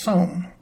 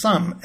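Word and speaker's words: some: Given the way it's spelled, it should be pronounced som, like home. In fact, earlier in the history of English it was. Over time, the long vowel O got shortened to something easier to say, That gave us sum as the pronunciation. That also created an irregular mapping between spelling, O-M-E, and sound, Notice that the sensible way to spell it, S-U-M, is used for an unrelated word, sum, as in arithmetic sum.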